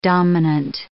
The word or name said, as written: dominant